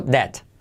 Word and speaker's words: debt: Eh, se escribe debt, pero la, de, la B no se dice, por tanto, debt. Como deteriorar. Debt.